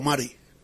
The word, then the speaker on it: Marty: Me gusta la manera en que un periodista de San Diego, Marty o Mari. – Marty. – Caswell. Se escribe Marty, pero yo he escuchado. – Sí, la T. – Se dice Mari Caswell.